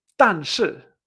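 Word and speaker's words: tan shi (但是): Pero en chino se dice tan shi. Tan shi, pam, pam.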